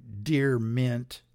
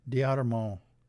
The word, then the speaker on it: DeArment: And this article was written by Alaric, capital A-L-A-R-I-C. I think it's a French last name, D'Armand. Okay, it looks like Dear Mint, but I think it's pronounced D'Armand. Okay, capital D-E-A-R-M-E-N-T.